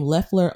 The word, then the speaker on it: Loeffler: And the other indication is people still aren't pronouncing her name correctly. You know, she's Loeffler and Loeffler and everything. So once people start pronouncing her name Loeffler on a consistent basis, she will have another indication that she has arrived, you know.